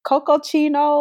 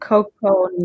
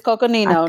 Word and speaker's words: Coconino: It's the Cocochino or Coconino National Forest. I think it's Cocochino. National Forest. – I think Coconino. – Is it Coconino? Yep, it's Coconino National Forest.